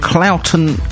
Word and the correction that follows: Claughton: I know I've said that wrong. It's Claughton Way.